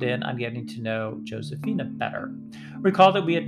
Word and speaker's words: Giuseppina: Her name is spelled the Italian way. It's a good thing I didn't try to look her up on LinkedIn, because it's spelled just like the Italian name Joseph, which is Giuseppe, except it's the female version, which is technically Giuseppina, G-I-U-S-E-P-P-I-N-A, but she pronounces it Josefina.